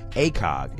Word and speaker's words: ACOG: And it's pronounced ACOG. It's A-C-O-G.